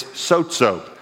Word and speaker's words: sozo: The Greek word is sozo. It's actually spelled S-O-Z-O, but it's pronounced S-O-D-E, sod, zo, Z-O, sozo.